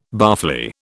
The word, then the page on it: barfly: British English